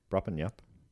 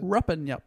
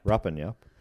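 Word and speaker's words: Rupanyup: Ruppin Yup. No, I didn't say that right, what was it? – Ruppin Yup. – Ruppin Yup.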